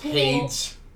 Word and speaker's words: Hades: It's pronounced Hades. – It's really not, though.